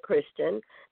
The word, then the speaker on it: Christian: I can give you my Facebook page, which is my first name, last name, Felicia Christian. That's spelled F-E-L-E-C-I-A, Christian, C-H-R-I-S-T-I-A-N.